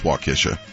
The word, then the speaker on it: Waukesha: Yeah, it's Waukesha.